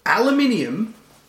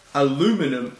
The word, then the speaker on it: aluminium: Were they just reading the word aluminium and went, aluminum? And everyone just went, that's aluminum.